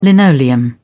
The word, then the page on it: linoleum: British English